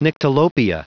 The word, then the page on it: nyctalopia: anglais